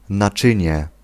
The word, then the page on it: récipient: France; [ʁe.si.pjɑ̃]